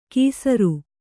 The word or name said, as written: ಕೀಸರು